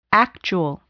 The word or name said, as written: actual